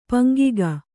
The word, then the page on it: ಪಂಗಿಗ: paŋgiga